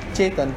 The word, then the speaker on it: Chetan: My name is Chetan. It's like Chetan is come from like a cheetah.